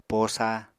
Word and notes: posa: apatani

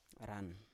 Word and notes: ran: Biak

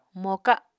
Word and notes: moka: Apatani

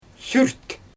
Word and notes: syrt: Xyzyl

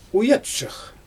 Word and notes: wɯjatʃɯx: Xyzyl

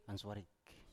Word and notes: answarik: Biak

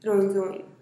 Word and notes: ruin zïin: TdVZ

